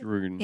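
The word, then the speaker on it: Druun: And then the dreaded monsters come, the Droon. Is that how you say it? – Droon. – Yeah.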